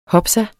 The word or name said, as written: hopsa